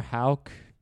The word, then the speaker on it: Houck: So I don't think any particular history was made other than Tanner Houck. Is that how you pronounce it? – Yeah.